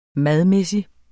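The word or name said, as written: MADMÆSSIG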